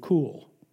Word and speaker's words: kuwl: Actually, it's pronounced in the Hebrew cool. It's spelled K-U-W-L. Pronounced cool.